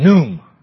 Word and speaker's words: Khnum: One, I don't know if I'm pronouncing it correctly, but K-H-N-U-M, Num.